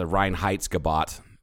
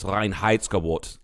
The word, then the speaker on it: Reinheitsgebot: Reinheitsgebot, but it's Reinheitsgebot. Like I fucking love saying that, because if you say it the proper way, a German will